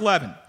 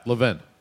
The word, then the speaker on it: Levin: So, Mark Levin. These are the guys who got in. Mark Levin. – Levin. – Mark Levin, excuse me.